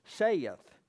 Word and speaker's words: saith: and if you're from the South, it's two syllables, saith the Lord.